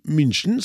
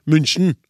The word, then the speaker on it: München: Jeg mener at det er feil, og at den u-en med tødla over i München skal uttales som u, i hus, mus etc. München.